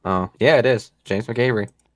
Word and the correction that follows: McAvoy: James McAvoy.